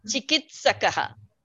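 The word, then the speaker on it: चिकित्सकः: Eshaha chikishtakaha saha chikishtakaha. – No. You're mispronouncing it. You're mispronouncing it, actually. Say it again. Eshaha chikitsakaha. He's a doctor.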